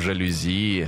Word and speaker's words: жалюзи: А, ты мне шторы не вози, мы повесим жалюзи. Жалюзи, французское слово, ударение на «и».